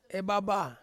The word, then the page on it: baba: Ansus